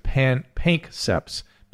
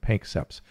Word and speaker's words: Panksepp: I mispronounced the name. It's Pankseps. PANKSEPP apostrophe S, seeking system. Pankseps.